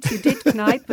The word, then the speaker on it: Kneipp: And you're celebrating a great proprietor, or a guy who really promoted that was Kneipp this year. I didn't say that right, probably, did I? – You did. Kneipp was a pastor, and yes, he tried this cure out on himself, basically.